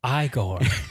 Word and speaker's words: Igor: Wait, how are you supposed to say it? – It's pronounced Igor. – Igor.